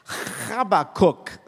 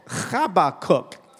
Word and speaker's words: Habakkuk: In Hebrew, he's pronounced Habakkuk. Habakkuk.